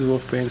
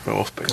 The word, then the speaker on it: Wolfsbane: Silent Princess, and we'll use Wolfbane for Garbanzo. – Wolfsbane.